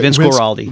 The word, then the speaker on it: Guaraldi: Vince Guaraldi.